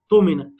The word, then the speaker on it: اؤتمن: طب احنا هنا بننطق واو؟ لا، فليؤدي الذي اؤتمن، الذي اؤتمن همزه ساكنه.